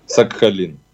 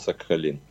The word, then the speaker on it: SAHALIN: Мы даже проверяли, как буду читать э, транскрипции на английском языке. Сакхалин. – А, вот так, чтобы все-таки звучало ближе к привычному нам произношению. – Да, 8 из 10 прочитают Сакхалин.